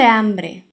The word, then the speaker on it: hambre: Me muero de hambre. Atención a la pronunciación de la palabra hambre. El sonido A no es nasal como en portugués. Es A. Hambre. Me muero de hambre.